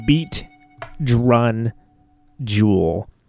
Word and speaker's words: Beat Drun Jewel: Beat Drun Jewel. So it's Drun, it rhymes with run, Jewel sounds like Jewel. That's how that goes.